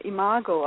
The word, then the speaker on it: Imago: For example, I engaged in a technique called Imago. I don't know, it's quite big in the USA. It's spelled I-M-A-G-O,